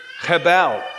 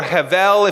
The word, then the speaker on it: hebel: It's hebel. Hebel. And it means hebel or hevel, if you like it that way, in the Germanic kind of pronunciation.